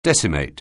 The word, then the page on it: decimate: anglais